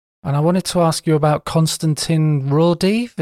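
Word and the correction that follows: Raudive: Is that how you say his name? – Rodeva. Konstantin Rodeva.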